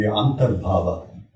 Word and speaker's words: antarbhava: Shishwain, the Sanskrit word seems to be antarbhava, that is between two existences.